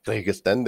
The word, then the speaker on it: Kyrgyzstan: Kyrgyzstan.